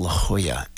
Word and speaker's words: La Jolla: Comedy Stop, Comedy Shop, something, out there in La Jolla, which is spelled La Jolla, but it's pronounced La Jolla.